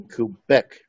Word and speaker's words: Kubec: So the guest that we have today is Ken Kubek, if I said it correctly, Ken.